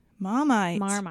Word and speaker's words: Marmite: What's Varmite? – Marmite. It's yeast extract.